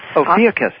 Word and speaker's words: Ophiuchus: Ophiuchus? – Ophiuchus, yeah.